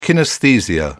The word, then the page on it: kinaesthesia: British English